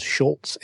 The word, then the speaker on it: Schulz: The title is called Schulz and Peanuts, a biography. There's no T in Schulz, strangely, even though it's pronounced that way.